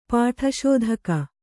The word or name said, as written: ಪಾಠಶೋಧಕ